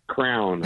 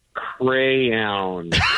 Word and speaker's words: crayon: Crown. – That's so weird. – All right, now say it the way you know it needs to be pronounced. – Crayon.